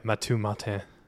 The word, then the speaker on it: Mathieu Martin: He went to Matthew Martin. We didn't know him. – Matthew Martin. – Sorry. Yeah, Mathieu Martin. – Yeah, it's French.